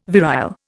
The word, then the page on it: virile: Indian